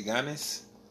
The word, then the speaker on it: Giannis: Make it a Y. Don't think G, make it Y. He ain't G. YG. – YG? – Yeah. – Giannis? – Giannis.